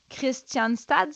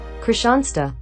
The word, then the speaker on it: Kristianstad: Christianstad, c'est ça? – Christianstad. Euh, oui, mais quelqu'un qui m'a dit qu'en Suède, ça ressemblait pas du tout à la prononciation de Christian Stahl. Kristianstad.